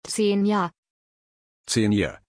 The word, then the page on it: Zenia: German